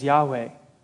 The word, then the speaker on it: Yahweh: It's four letters of the Hebrew alphabet that we sometimes pronounce as Yahweh.